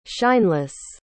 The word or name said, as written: shineless